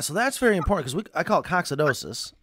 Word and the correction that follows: coccidiosis: right? And that's the actual, like, the after effect of the coccidia How do you pronounce it? – Coccidia are the parasites, and coccidiosis is the disease.